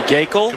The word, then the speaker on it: Gaeckle: It was Gabe, I don't know how you pronounce that, Geckel? Right-hander out of Arkansas. So that's the pick number 73. – That'd be Geekle. – Competitive balance pick. – Could be Geekle. – Yep. G-A-E-C-K-L-E.